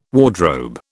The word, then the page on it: wardrobe: British English